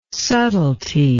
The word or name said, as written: subtlety